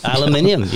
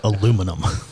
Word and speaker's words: aluminium: Aluminium. We spell it with an extra I. Right. What can I say? We say capillary and you say capillary. – He's saying aluminum.